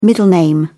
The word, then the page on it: middle name: British English